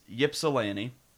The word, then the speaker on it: Ypsilanti: It is Ypsilanti.